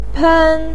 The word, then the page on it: pen: 1